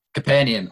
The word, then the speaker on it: Capernaum: Capernaum. Capernaum.